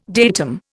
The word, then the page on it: datum: Indian